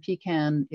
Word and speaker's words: pecan: And my daughter recently moved to Las Cruces, and she says there, it's a big industry there, but they say it pecan. So that's a third pronunciation.